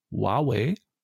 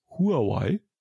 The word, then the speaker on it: Huawei: Und zwar hat ja Huawei, so werden sie, glaube ich, wirklich ausgesprochen. Jeder in Deutschland kennt sie aber als Huawei.